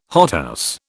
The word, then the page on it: hothouse: British English